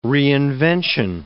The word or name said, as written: reinvention